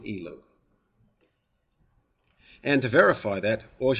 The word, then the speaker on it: Elul: And in Hebrew, the word is Elul, Elul. That's how you say it.